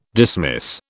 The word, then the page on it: dismiss: British English